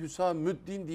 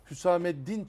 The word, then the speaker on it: Hüsameddin: Dolayısıyla Hazret'in ismi Hüsamüddin değildir. Belki mümkün tarihte yaşamış başka biri olabilir o. – Eyvallah. – Hüsameddin Çelebi dediğinizde tam ismini telaffuz etmiş olursunuz.